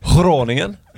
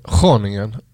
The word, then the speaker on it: Groningen: Det har liksom satt sig den uttalat. Det är inte många som säger Groningen, utan där är det Groningen ändå.